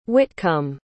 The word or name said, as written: hwitcumb